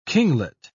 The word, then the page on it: kinglet: British English